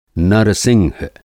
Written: नरसिंह

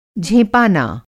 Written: झेंपाना

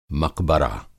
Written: मक़बरा

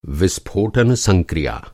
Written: संक्रिया

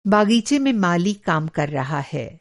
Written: बाग़ीचा

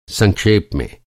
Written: संक्षेप

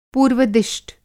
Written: पूर्व-दिष्ट

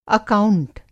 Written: अकाउंट

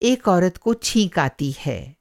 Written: छींक

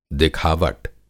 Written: दिखावट

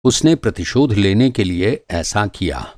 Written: प्रतिशोध

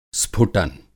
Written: स्फुटन

